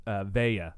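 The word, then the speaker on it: Valle: Valet. – Valet. – If I'm saying it right. Or Vaya. – Oh, what is it? V-A-L-L. – E. – Yeah, it's Vaya.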